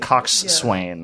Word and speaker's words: coxswain: That's why I figured it was like front, back, and that word is spelled like coxswain. – Coxswain. – Yeah. But you pronounce it coxswain.